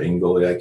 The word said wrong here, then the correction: Algolia: I can't remember how it's pronounced. – Algolia.